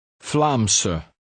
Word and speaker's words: Vlaamse: The ending is unstressed. And pronounced S. Vlaamse.